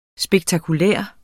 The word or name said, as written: spektakulær